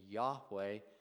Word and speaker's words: Yahweh: We're not sure how to pronounce it. It's often been translated or mistranslated or transliterated as Jehovah, but probably closer to the Hebrew, at least, if not completely accurate, is the name Yahweh.